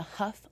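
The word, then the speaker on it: Hough: Just my full name, Chelsea Maria Huff, like Tuff, H-O-U-G-H. I say Huff like Tuff just so you know how to pronounce it.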